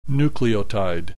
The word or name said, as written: nucleotide